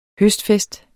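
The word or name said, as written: Høstfest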